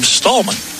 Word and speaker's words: Stammen: Yeah, Versailles has three girls on their team, all spelled the same, S-T-A-M-M-E-N. One girl pronounces her name as Stammen, and two of them pronounce their name Stallman.